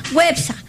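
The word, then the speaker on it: Güepsa: ¿Cómo se dice a los que nacieron en Huespa? Perdón, Huepsa. Websa. ¿Ah? Con diéresis.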